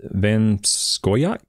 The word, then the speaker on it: Van Scoyoc: So Christina Bigelow, yourself, Smith, Van Skoyak, did I say that right? – Yeah.